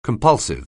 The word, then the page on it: compulsive: anglais